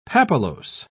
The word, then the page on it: papillose: British English